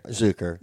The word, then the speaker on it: Zuker: Zuker or Zucker? – Zuker. – Zuker. I was saying it right. – You were. – You said I wasn't before. So it is Zucker. Zuker. – It's Zuker. It's not...